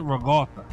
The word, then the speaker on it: ricotta: First of all, you pronounce it ragotta, all right? – No, I don't. Maybe you do. – Well, you're supposed to. This is the way you're supposed to pronounce it.